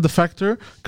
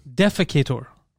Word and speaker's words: defector: Defecator. – It's not defecator. – Yeah, North Korean defecator. – Defector, when you defect.